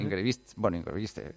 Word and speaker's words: Ingebrigtsen: Ingebrigtsen, ¿no? – Ingebrigtsen. Eso ya cada uno. – Ingebrigtsen gravedad, sí. – Según la pronunciación.